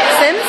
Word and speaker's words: Sims: Sims. S-I-M-S.